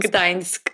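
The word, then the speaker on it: Gdańsk: Gdansk.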